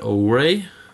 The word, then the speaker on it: OURE: Og så annonserte de et spill som heter Ouray, som skrives O-U-R-E. Jeg hadde aldri trodd at jeg skulle uttale det sånn. Det er et PS... Ouray er et PS4-spill som ble annonsert og sluppet samtidig, omtrent.